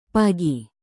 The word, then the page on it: pagi: Indonesian